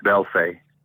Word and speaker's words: Belfay: Oh, it's pronounced Belfay. – Belfay. – B-E-L-F-A-Y.